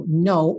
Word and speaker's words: know: Know, o K é mudo, tá?